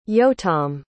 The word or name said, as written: yotam